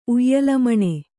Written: ಉಯ್ಯಾಲೆಮಣೆ

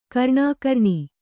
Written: ಕರ್ಣಾಕರ್ಣಿ

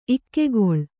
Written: ಇಕ್ಕೆಗೊಳ್

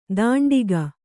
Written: ಧಾಂಡಿಗ

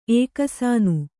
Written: ಏಕಸಾನು

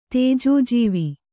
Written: ತೇಜೋಜೀವಿ

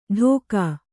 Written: ಢೋಕಾ

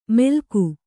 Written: ಮೆಲ್ಕು

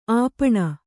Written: ಅಪ್ಪಣೆ

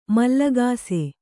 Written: ಮೂಲಗಿಸು

